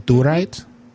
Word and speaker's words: Dorite: My name is Durite. – How you spell it? – D-O-R-I. – B? B or D? – D as in Dick. – Uh-huh. – Big Dick. D-O-R-I-T-E. Mm-hmm. Do right.